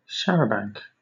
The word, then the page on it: charabanc: British English